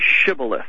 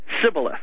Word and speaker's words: Shibboleth: That S-H, sh sound was not in the dialect or the language of the tribe that had attacked them. They would say just the long S, the S, Shibboleth.